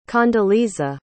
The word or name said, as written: condoleeza